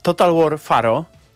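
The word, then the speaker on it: Total War: Pharaoh: Ja zapomniałem, że ta gra istnieje, więc tak, przypomnijmy, że chodzi o Total War Faro. Bo tak się to wymawia.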